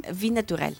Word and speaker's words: Vie Naturelle: Vie Naturelle. Ese, pues, pero en francés, ¿no? – Vie Naturelle. – Vie Naturelle.